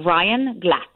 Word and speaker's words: Glatt: So he's called Ryan Glatt. – How do you spell that, his last name? – G-L-A-T-T.